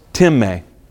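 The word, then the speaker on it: timē (τιμή): Now it's spelled time, but it's pronounced timē.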